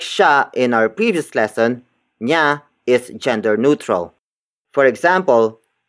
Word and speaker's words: niya: Niya is spelled as N-I-Y-A.